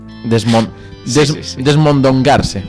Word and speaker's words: desmondongarse: El verbo es desmongar o desmongarse. Podría ser transitivo también. – Desmongarse. – Viene, es un verbo que viene. Desmondongarse. Perdón, lo dije mal, ¿no? – Sí. – Bien. Desmondongarse. Que viene de mondongo.